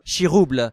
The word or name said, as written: Chiroubles